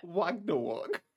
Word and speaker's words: Ragnarok: Ragnarok. – Ragnarok.